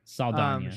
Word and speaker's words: Saldana: Saldana.